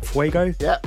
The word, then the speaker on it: fuego: I think I'm saying this right. Fuego? – Yep.